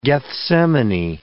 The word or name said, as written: Gethsemane